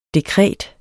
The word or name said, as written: dekret